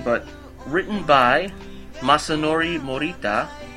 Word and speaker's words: Masanori Morita: But it's yes, Masanori Morita. you said it right.